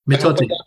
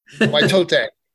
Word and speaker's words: Mitote: Mitote. – Mitote. One of these days I will get it correctly.